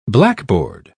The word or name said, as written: blackboard